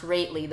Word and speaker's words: Rately: It's pronounced Rately, though it looks like Rothley.